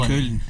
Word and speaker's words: Köln: Köln. – Como é que se chama? – Köln. – É assim que se pronuncia.